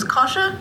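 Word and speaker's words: Koshche: Does Satan you pronounce it was Kasha? – Yeah.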